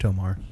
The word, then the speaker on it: To'marr: Well, maybe you should have asked these questions before, Tomas. – All right. – It's pronounced Tomar.